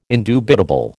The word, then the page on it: indubitable: American English